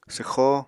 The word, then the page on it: swxó: Apatani